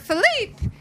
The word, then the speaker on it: Philippe: Alexander Philippe, who is a filmmaker. My voice got really high. – Is that the French pronunciation? – That is the French pronunciation.